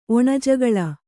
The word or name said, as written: ಒಣಜಗಳ